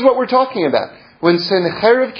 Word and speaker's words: Sennacherib: Sennacherib is how we pronounce it in Hebrew.